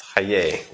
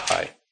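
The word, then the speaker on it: Chayei: Chaye, it's Chaye, not Chai.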